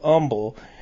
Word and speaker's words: humble: but in southern states they pronounce it Humble, like you would you wouldn't pronounce the H in honest.